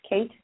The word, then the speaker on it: Kate: It's Kate Carroll. And if you pull up Kate Carroll Ingram, I'm the only one. – Got it. And Kate is spelled with a K, so that's K-A-T-E, yep. – And then her last name is Carroll, C-A-R-R-O-L-L. Yeah, C-A-R-R-O-L-L, right? – Yep, perfect.